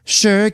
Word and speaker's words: Sure: It's from the brand Sure, but you pronounce it like this. Sure, Gail.